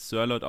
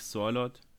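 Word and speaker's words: Sørloth: Also man spricht ihn tatsächlich Sirlot aus, nicht Sirlot, wie viele Leute ihn falsch aussprechen.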